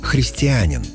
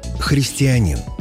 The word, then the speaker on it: христианин: Одно из них – «христианин» многие произносят с ударением на третий слог – «христианин».